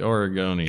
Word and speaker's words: Oregonian: But it's Oregon, so Oregonian. – it's the newspaper? – The Oregonian. – How do you say it, man? – The Oregonian. – There you go, Oregonian.